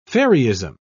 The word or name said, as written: fairyism